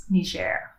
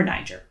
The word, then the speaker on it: Niger: In 1926, the capital of this Republic of Northwest Africa was moved from Zinder to Niamey, that is Niger or Niger. Either pronunciation's okay.